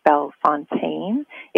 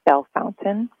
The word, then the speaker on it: Bellefontaine: Rush was buried last Wednesday in St. Louis, Missouri, at a wonderful cemetery called Bellefontaine. It could also be pronounced Bell Fountain Cemetery in St. Louis, Missouri.